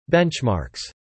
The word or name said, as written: benchmarks